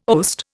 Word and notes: oast: Indian

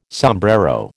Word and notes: sombrero: American English

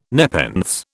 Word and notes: nepenthes: British English

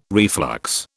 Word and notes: reflux: British English

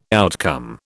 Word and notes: outcome: British English

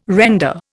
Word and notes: render: Indian

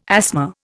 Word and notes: asthma: Indian

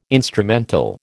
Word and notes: instrumental: American English

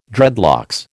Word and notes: dreadlocks: American English

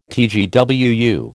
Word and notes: TGWU: American English

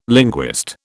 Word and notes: linguist: British English

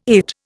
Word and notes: IT: Indian

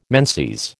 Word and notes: menses: American English

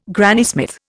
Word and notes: Granny Smith: Indian

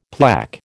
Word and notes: plaque: American English

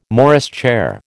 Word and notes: Morris chair: American English

